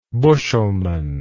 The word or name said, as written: bushelman